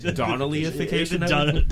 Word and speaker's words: Donnellyification: Denellification. – Because is that how you say it? – Donnellyification. – Donnellyification. – Right.